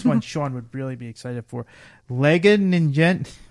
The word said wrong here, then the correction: Ninjago: I can't even pronounce Ninjago. – It. Thank you, Ninjago.